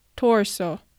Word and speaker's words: torso: 日本のカタカナではトルソーと表現されることが多いようですが発音はト s o ー、 o r ソーになります。カタカナの「ル」にしてしまうと L の発音が入ってしまうのでしっかりと R の発音に変えて torso